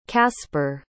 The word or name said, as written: casper